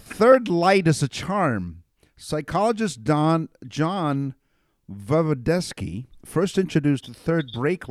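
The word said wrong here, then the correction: Voevodsky: Vovdovsky is his name, because I mispronounced it the first time, studied the effects of driving on attention, talking about, you know, distracted driving.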